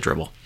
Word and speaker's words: Dribbble: Just pronounce it Dribbble.